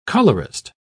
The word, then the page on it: colourist: British English